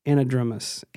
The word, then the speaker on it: anadromous: Andromedus? – Not quite. Anadromous.